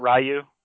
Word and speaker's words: Ryu: And then Street Fighter was, I don't, if people pronounce it, I pronounce it Ryu.